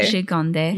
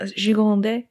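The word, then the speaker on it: Gigandet: Gigande. – Yeah, Gigande.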